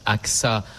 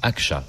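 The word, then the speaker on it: Ağca: Alors, ça se prononce Aksa en français, mais en turc, Akça, puisqu'il était turc.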